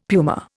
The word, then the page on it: puma: Indian